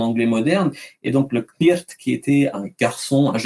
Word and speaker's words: cniht: ça s'écrit C-N-I-H-T, en le prononçant en anglais de l'époque, ça donnerait Knirt.